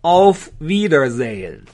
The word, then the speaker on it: auf Wiedersehen: 叫 auf Wiedersehen。这个 auf 呢是一个介词，wieder 就是英语的 again，就再一次，sehen 就是 see 啊，就是再再一次见面啊，非常的直白的翻译，Wiedersehen 就是再次见面。 Auf Wiedersehen 就是为了下一次的见面或者下次见啊，所以就很长。